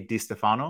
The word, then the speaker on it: Di Stefano: I'm here chatting with Catie Di Stefano, if I've said that properly.